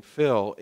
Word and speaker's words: Phil: First is he is the first person I met that truly mastered the art of using the short version of my name, Phil, in two syllables. I would see Stan and he would say, "Feel," like that all the time.